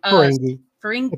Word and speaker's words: Ferengi: and that was like, oh, yeah, all 10,000 years of Ferengi. I can't say their name. – Ferengi. It's a hard G.